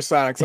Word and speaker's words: psionics: And now for the second part of segment one, we're going to talk about power category psychionics. That's how you pronounce that, right? – Sure. No, not at all. Oh, okay. Go ahead and throw up the PDF for psionics.